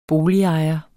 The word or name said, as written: boligejer